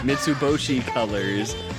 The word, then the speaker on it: Mitsuboshi Colors: Mitsubishi Colors? – No, not Colors. – Mitsuboshi Colors, not Mitsubishi.